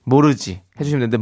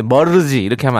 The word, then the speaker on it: 모르지: 모르지 해주시면 되는데 머르지 이렇게 하면 안 될 것 같습니다.